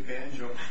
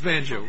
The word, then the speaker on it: Danjo: Case just argued will be submitted for decision, and the court will hear argument next in Banjo versus Ayers. If I pronounced that correctly, D-A-N-J-O. – Actually, Banjo. – It is Banjo.